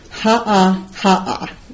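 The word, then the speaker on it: Ha ha ha ha (haʻahaʻa): Ha ha ha ha. I said it wrong again. It's hard not to add the ha ha in there. Ha ha ha ha, meaning humility, to be expressed with modesty.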